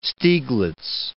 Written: Stieglitz

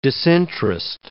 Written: disinterest

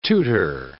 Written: tutor

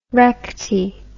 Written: recte

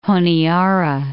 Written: Honiara

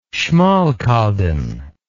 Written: Schmalkalden

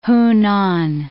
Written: Hunan

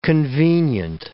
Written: convenient

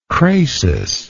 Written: crasis

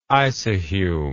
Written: isohume

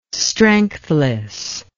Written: strengthless

